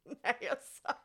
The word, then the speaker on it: Nassau: Nassau. – I didn't say anything. And you actually, I thought you pronounced it pretty well right there.